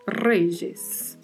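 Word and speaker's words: reyes: Então vamos falar reges. A pronúncia fica aí como se fosse um J tá? La Y seguida de vogal vai ter um som semelhante ao J do português. E aí vamos pronunciar reges.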